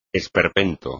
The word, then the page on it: esperpento: espagnol